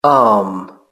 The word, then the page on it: arm: British English